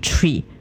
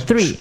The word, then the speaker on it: three: 然后这个国家里面所有的人讲一二三的三的时候，发音都是 tree，就是一二树，他们说的不是 three，他们说的是 tree 的一个一个。